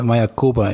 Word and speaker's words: Mayakoba: Ik zie Frederik lachen, want ik had waarschijnlijk weer verkeerd uitgesproken. ik denk dat het uh, Mayakoba is.